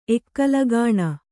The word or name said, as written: ಎಕ್ಕಲಗಾಣ